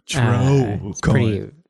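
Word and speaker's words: TreauxCoin: T-R-U? – No, it's spelled like T-R-E-A-U-X. Like... – TrueCoin. It's a TrueCoin? – Sounds pretty elite.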